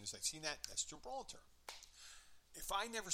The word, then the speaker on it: Gibraltar: And she goes, Gilbraltar, which is Gibraltar.